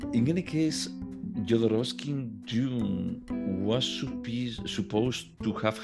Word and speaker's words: Jodorowsky: Uh, yeah, Jodorowsky.